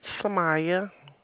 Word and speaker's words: Sumaya: Samaya. – Can you spell that? – Yes, it's S as in Sam. U M. A-Y-A. – That's S-U-M, huh? Oh, okay. – Did you hear S-U-M-A-Y-A?